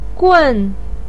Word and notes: gun: 4

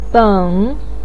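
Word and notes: beng3: tone 3

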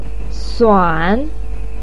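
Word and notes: suan: tone 3

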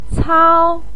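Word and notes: cao1: tone 1

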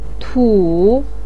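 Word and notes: tu: tone 3

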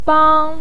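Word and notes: bang1: tone 1

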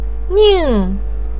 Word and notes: ning: 4

